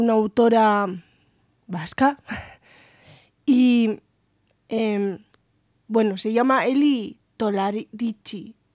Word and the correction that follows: Tolaretxipi: No, el apellido lo he dicho mal. Tolarechipi.